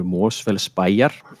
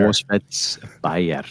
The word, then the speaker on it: Mosfellsbæjar: It's Golfklubur Mosfellsbæjar. rolls off the tongue smoothly. Mosfellsbæjar. – Mosfellsbæjar. – Yes.